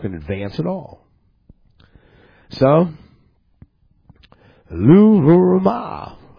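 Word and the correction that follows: Lo-ruhamah: I didn't say it right again, it's Loruhama.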